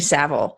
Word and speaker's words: Savile: By the way, I just looked up how to pronounce the name of the guy I was talking about at the BBC. It was Jimmy Savile. – Savile, yep.